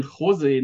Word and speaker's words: José: Heute haben wir den Jose Neff zu Gast. Und ich habe am Anfang nochmal geklärt, wie man Jose ausspricht, nämlich in der Tat mit der Betonung auf dem O, wo der Akzent auf dem E ist.